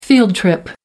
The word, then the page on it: field trip: American English